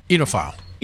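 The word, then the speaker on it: oenophile: Enophile. – Enophile. Oh, that's easy. – It's pronounced with an E. – Yes. – Enophile. – Spelt with an O, pronounced with an E. Silent O.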